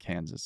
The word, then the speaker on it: Kansas: It is the English spelling, though, so naturally you pronounce the final S. So if France had nothing to do with this, it would be Arkansas and Kansas.